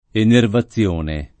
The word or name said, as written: enervazione